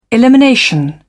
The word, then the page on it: elimination: British English